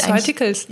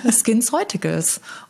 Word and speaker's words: SkinCeuticals: SkinCeuticals. – Ja, genau. SkinCeuticals.